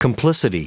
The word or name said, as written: complicity